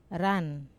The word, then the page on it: ran: wamesa